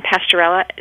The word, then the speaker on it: Pasteurella: It's Campylobacter. You got it right. And Pasteurella.